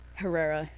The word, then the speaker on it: Herrera: Herrera. Herrera. – Herrera. – Yes, there we go. That's the correct way to say it.